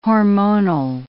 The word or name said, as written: hormonal